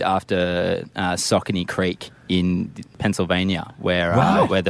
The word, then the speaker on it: Saucony: Yeah, no, it is definitely pronounced Saucony, although I've heard a lot Saucony? – Of different iterations. Saucony is definitely how it's more commonly said.